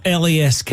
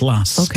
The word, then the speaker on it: LASK: It's not LASK, it's pronounced LASK.